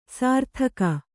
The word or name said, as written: ಸರಿದೂಗು